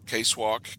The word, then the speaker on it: KSWCC: We have our own Star Wars Kentucky Collectors Club, Kentucky Star Wars Collectors Club, KSWCC, or as we like to pronounce it, Case Walk, because we're from the hills and that's the way we like it.